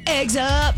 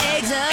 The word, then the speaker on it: Eggs Up: You're saying it wrong. – Am I? – It's Eggs Up, like Cameo. Like Word Up. Eggs Up.